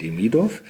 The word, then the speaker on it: Demidov: Das Buch heißt Fone Kvas, das ist ein jiddischer Slang-Ausdruck und geschrieben ist es von Georgi Demidov. Auch gut ausgesprochen, ein Glück. Man muss immer genau gucken, wo man den Akzent setzt, Demidov.